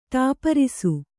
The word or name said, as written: ಟೆಪ್ಪರಿಸು